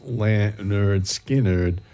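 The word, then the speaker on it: Lynyrd Skynyrd: Hailing from Florida, pronounced Leonard Skinnerd, was their first album, 1973, from which we heard Simple Man.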